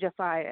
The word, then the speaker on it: Jeffiah: It's pronounced Jeffiah, but everybody says it's Jeffiah. Everybody says it like that, so don't feel bad.